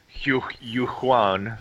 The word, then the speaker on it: Julian: It's like a cross between a Z, a CH, a GH. It's Julian.